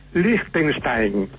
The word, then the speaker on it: Liechtenstein: La pronunciación correcta es Liechtenstein.